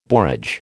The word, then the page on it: borage: American English